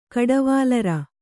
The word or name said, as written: ಕಡವಾಲರ